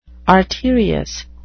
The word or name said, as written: arterious